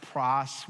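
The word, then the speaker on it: pros (πρός): It's a Greek word that's pronounced pros.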